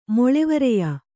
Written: ಮೊಳೆವರೆಯ

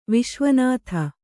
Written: ವಿಶ್ವನಾಥ

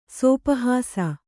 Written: ಸೋಪಹಾಸ

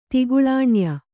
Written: ತಿಗುಳಾಣ್ಯ

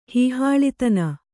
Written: ಹೀಹಾಳಿತನ

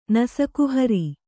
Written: ನಸಕುಹರಿ

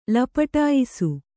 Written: ಲಪಟಾಯಿಸು

